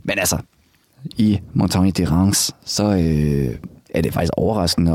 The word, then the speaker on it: Reims: Så sidder man og tænker, for det staves R-E-I-M-S. – Ja. – Men det udtales Reims. – Mm-hmm. – Altså ligesom France uden F.